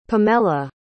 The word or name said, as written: pamella